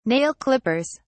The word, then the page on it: nail clippers: British English